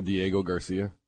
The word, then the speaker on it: Diego Garcia: Was that when you were in Diego Garcia? – You actually pronounced that correctly.